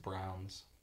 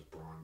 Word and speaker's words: Braun: I thought it was Braun, but then the announcer saying Brown.